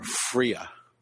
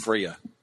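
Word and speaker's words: Freyja: How do you say that word again? – Freya. – Freya. – It's F-R-E-Y-J-A. I pulled up the pronunciation. – Freya. – It's Freya.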